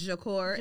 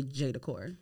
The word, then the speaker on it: J'decor: pronounced Jacor, Jacor in upholstery, but uh, most people, I make it easy for them to just say J Decor.